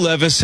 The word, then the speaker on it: Levis: Think if Will Levis had been a world-class Hall of Fame-level quarterback, even though it's pronounced differently, wouldn't Levi's have to, at some point, do an endorsement with him? – Yeah, of course.